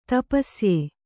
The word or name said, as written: ತಪಸ್ಯ